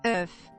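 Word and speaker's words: œuf: Oof.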